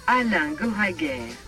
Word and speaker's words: Alain Goraguer: Alain Gereguer.